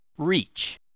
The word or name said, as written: reach